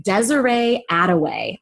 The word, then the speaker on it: Desiree Adaway: Her name is Desiree Adaway. I love that it rhymes so that I can... – It totally rhymes.